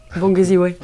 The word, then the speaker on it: Bangeziwe: alors, je vais essayer de le prononcer comme tu m'as appris. Bangeziv, c'est ça? – Bangeziwe.